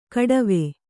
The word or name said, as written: ಖಟ್ವ